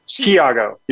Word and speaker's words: Thiago: Thiago, you're right there.